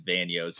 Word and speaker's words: Vanyo: All right, so we got Steven, is it Vanyo? I'm sorry, I don't know if I pronounced that right. – Vanyo is correct, yes.